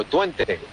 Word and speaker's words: Twente: Twente y el, o Twente, como se pronuncia aquí, y el Real Club Deportivo Español, aquí en la localidad de Gord.